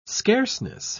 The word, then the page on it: scarceness: British English